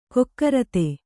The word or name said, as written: ಕುಕೃತ